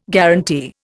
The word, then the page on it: guarantee: Indian